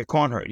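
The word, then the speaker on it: Coornhert: And I was also thinking of, you mentioned this other writer, Dirk Cornhert. Is that how you pronounce it? – Yeah, Cornhert. Yes,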